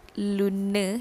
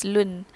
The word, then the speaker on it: lune: So, dia sebut Luna. Dia sebut E tu. But the correct pronunciation is lune.